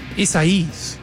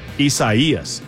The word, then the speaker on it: Isaías: It's four syllables, guys. – Isaías. – That's three syllables. – Isaías. – Isaías. You did it.